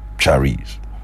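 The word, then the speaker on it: charis (χάρις): From the Greek, the word charis. That's the pronunciation, charis.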